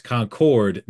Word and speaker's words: Concord: Up north they say Concord, but it's Concord, North Carolina.